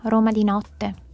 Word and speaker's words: Roma di notte: I'd say Roma di sera, Roma di notte.